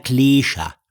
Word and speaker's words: Kläscher: Die Betonung läuft auf E. Kärntner Kläscher. – Kläscher. – Vielleicht für die, du könntest den Kärntner Kläscher mit 2 E schreiben. – Ja, okay, wie der Klee, der deutsche Klee.